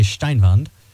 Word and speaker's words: Steinwand: Nestled among acres of farmland and the close-knit community surrounding Colby, Ambrose, now, his last name is spelled Steinwand, but that looks very German, so we're going to say Steinwand.